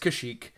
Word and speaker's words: Kashyyyk: one of the Imperial people says that Kazook is on lockdown. – Okay. – And I thought he was talking about a different planet, but turns out it's Kashyyyk. – He just didn't get the pronunciation.